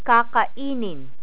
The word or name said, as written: kakainin